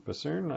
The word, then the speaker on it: Busan: Peninsula is a Korean one it's a sequel to uh, Train to Busan, Busan. Uh, uh, ah, yep. Pronouncing that right.